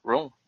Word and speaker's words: Rom: Rome, how do you say it? Rome, that's right.